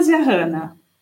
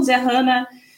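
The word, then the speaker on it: Zyahana: É arroba psi Camila Zerrana. É, Camila com C normal, Zerrana é Z-Y-A-H-A-N-A.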